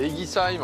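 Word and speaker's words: Eguisheim: Alors, comment tu prononces le nom de ton village? C'est Eggisheim ou Eggisheim? – Alors, c'est Eggisheim, en fait.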